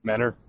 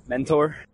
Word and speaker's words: Mentor: Menner. – Mentor. – Menner. – Mentor. – Is it Mentor or Menner? Let's put this to rest for a final time.